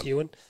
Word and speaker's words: Euan: Ewan, I'm not sure if I'm saying that right. E-U-A-N. – Yeah, that's Ewan.